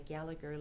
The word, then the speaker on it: Gallagher: Well, on the Gallagher side, and I guess I pronounced Gallagher wrong. There's no G-H. It's Gallagher.